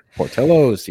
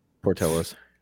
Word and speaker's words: Portillo's: Is that how you say it? I was trying to be all like Portillo's. – I don't know what accent I was giving that, but apparently the wrong one.